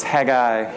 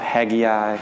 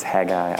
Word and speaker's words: Haggai: So how do we pronounce this word? We learned last week it's Haggai. It is not Haggai. So it's Haggai, I promise.